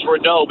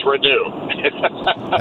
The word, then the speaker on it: Reneau: Malik Renew or Renault? – Well, we all think it was Renault before we found out that it was Renew.